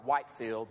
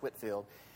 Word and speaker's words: Whitefield: If you see it in print, it looks like Whitefield, but it's pronounced Whitefield.